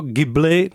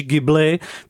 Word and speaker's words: Ghibli: Takže to studio pojmenoval právě podle jednoho z nich, ale ten italský letoun, eh, který se teda asi vyslovuje spíš Ghibli,